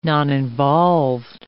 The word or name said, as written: noninvolved